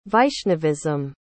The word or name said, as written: vaishnavism